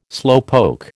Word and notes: slowpoke: American English

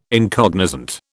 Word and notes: incognizant: British English

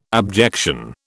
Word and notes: abjection: British English

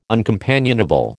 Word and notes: uncompanionable: American English

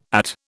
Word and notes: At: British English

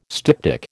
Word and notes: styptic: American English

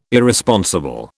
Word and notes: Irresponsible: British English

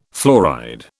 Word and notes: fluoride: British English

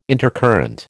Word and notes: intercurrent: American English

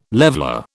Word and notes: leveller: British English

